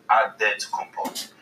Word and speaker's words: Antetokounmpo: So it's Antetokounmpo. – Wait, so what's Antetokounmpo? What way is it? Yes, I'm gonna go with the Greek way. – I mean, Nigerian way. – There's no Adetokounmpo. That's how you say it. – Listen. – You gotta say with the D. So it's Adetokounmpo.